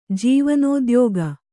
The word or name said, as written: ಜೀವನೋದ್ಯೋಗ